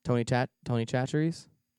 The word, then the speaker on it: Tony Chachere's: Tony Chachere's? – don't know. – And that's how you pronounce it too. I checked with my Creole friend.